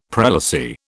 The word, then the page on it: prelacy: British English